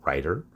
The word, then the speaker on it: Rytr: It's spelled R-Y-T-R, Writer.